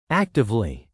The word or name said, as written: actively